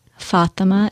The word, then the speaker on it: Fatima: So Afroz is spelled A-F-R-O-S-E. Fatima is F-A-T-I-M-A. And Ahmed is A-H-M-E-D.